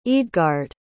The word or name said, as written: eadgard